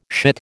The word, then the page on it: shit: American English